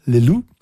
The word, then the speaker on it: Le Loup: Le Loup. Ich nehme an, das P spricht sich nicht am Ende, ist stumm. Le Loup, der Wolf.